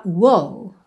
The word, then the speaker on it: whoa: Então, atenção a essa palavrinha que ela se escreve W-H-O-A, mas você fala Whoa, ok?